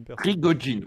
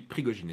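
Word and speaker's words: Prigojine: je le prononçais Prigogine, mais euh, je suis je, suis pas du tout sûr de la vraie prononciation du truc. Hein. Euh, je pensais que c'était Prigogine. Prigogine, oui, Prigogine, excusez-moi.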